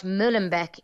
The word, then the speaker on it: Molenbeek: Molenbeek is the name of the area where a lot of the suspects of the Paris attacks have been living.